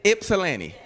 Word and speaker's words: Ypsilanti: Ypsilanti.